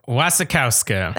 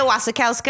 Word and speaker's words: Wasikowska: It's written by Guillermo del Toro and Matthew Robbins, directed by Guillermo del Toro, starring Mia Wasikowska. Is that how you say it? Shit. I should have looked up an interview. – Wasikowska. – That sounds good. It's starring Mia Wasikowska as Edith Cushing, Jessica Chastain as Lucille Sharp, and Tom Hiddleston as Thomas Sharp.